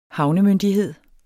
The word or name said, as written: havnemyndighed